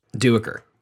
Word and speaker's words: Duiker: Starting with Diker, one of my favorite characters. – Duiker. Love him. – But that's how they say it in the audiobook.